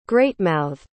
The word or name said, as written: greatmouthed